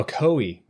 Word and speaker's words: Ocoee: Ocoee. – I looked it up. – Oh, Ocoee.